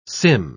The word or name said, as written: sim